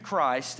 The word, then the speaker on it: Christ: my name is Jude Christ, and you spell it C-H-R-I-S-T.